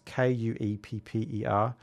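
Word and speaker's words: Kuepper: So Ed Cooper, he's of German background. Of course, his name is pronounced Cooper.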